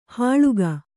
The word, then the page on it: ಹಾಳುಗ: hāḷuga